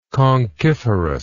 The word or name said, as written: conchiferous